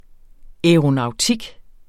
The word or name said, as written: aeronautik